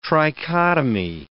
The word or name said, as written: trichotomy